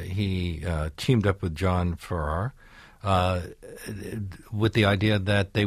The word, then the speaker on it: Farrar: But his name is pronounced Farrar because It is. It's Farrar, yes.